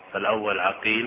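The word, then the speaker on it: عَقيل: فهنا اتفقت الأسماء واختلفت أسماء الآباء لا في رسمها وإنما في نطقها، فالأول عقيل بفتح العين والثاني عقيل بضمها، فالأول يقول الحافظ نيسابوري والثاني فريابي، وهما مشهوران وطبقتهما متقاربة.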